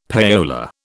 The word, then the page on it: payola: British English